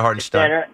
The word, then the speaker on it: Hartenstein: Yeah, Isaiah Hartenstein.